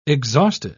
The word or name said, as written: exhausted